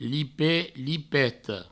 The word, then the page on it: lipet, lipeta: provençal; lipet, lipeto